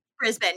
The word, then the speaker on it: Brisbane: Is that how you say it? Brisbane? – Brisbane, yeah.